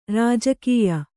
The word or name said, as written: ರಾಜಕೀಯ